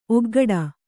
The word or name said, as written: ಒಗ್ಗೊಡೆ